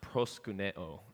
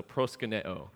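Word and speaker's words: proskuneo (προσκυνέω): And it's the same Greek verb, and it's pronounced proskuneo.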